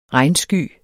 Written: regnsky

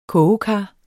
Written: KOGEKARS

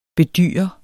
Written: bedyre

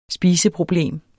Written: spiseproblem

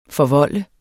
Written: FORVOLDTES

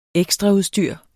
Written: ekstraudstyr

